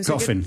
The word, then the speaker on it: Goffin: So Goffin.